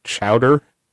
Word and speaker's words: Chaudoir: it looks like Chaudoir because it's French or something like that, but it's actually pronounced like Chowder.